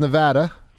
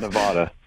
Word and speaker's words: Nevada: He's way out in Reno, Nevada, and it is pronounced Nevada for all you Pennsylvanian listeners. – Don't put a W or an H in there. Not Nevada.